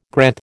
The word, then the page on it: Granth: American English